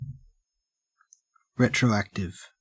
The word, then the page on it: retroactive: Australian English